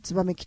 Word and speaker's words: 玄鳥: これが玄鳥至ると書いてツバメ来たると読みますからね、の玄鳥、それに、天に女と書いてツバクラメとも読むんですね。